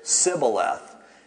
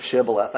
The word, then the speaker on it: Shibboleth: They would say Sibboleth instead of Shibboleth.